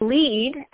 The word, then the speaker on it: lede: The lead, and it is actually spelled that way, I promise I didn't spell it wrong. It's spelled L-E-D-E.